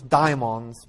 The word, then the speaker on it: daimons: Although I like to pronounce this daimons because in Greek, the demons don't have to be evil.